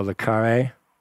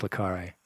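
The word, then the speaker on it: Le Carré: How do you pronounce the spy intrigue novelist John Law? – Oh, Le Carre.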